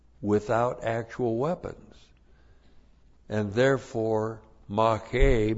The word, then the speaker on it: mache: And the key word here is mache. M-A-C-H, long E, mache.